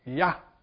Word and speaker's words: Yah: Yah. This is how you pronounce it in Hebrew.